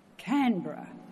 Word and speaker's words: Canberra: announced the name Canberra with a firm accent on the can.